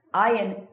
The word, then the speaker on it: Ayin: Ayin is silent. Okay, it's pronounced it's silent when you pronounce it.